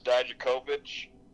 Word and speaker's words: Dijakovic: The three people who were there was obviously Tommaso Ciampa, Dominic Dijakovic. Am I saying that right? – I think so. I always pronounce it wrong, but that sounds right.